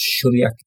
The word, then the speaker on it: Šuljak: Šuljak is a Croatian name. Normally the S has a inverted like hat accent with looks like a V, which means it should be pronounced Šuljak.